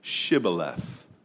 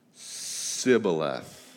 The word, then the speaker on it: Shibboleth: Now, there's no specific hidden details in the word other than the fact that the Ephraimites were not able to pronounce that sh sound. And so the Gileadites say, come right on through. All you have to do is prove that you're a Gileadite and say Shibboleth. They'd get up there and they'd say Sibboleth because they couldn't pronounce it.